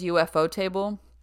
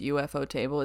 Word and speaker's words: Ufotable: The studio is UFO Table. I don't technically think it's pronounced UFO Table. It's Ufotable, but I like to call it UFO Table.